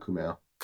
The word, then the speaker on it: Kumail: Kumail, how do you say it? – Just call him Kumail. – Kumail? I don't even know if we're saying that right. – That's how they say it in the movie.